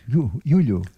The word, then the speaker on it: luglio: La pronuncia qua è luglio. – Perché voi dite giugno e giulio, è molto simile, sì.